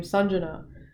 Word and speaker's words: Sanjana: It's like, I don't get mad if people don't pronounce my name Sanjana, which is the Indian way to say it, but it's like, that's not necessarily about my culture at all.